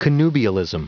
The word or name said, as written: connubialism